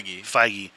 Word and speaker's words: Feige: how do you say his last name? It's not Feig, is it? – Feige. – Because there is a person whose same spelling goes by Feig.